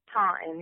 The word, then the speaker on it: Taunton: Is that how you say it? – Yeah, Taunton, yep.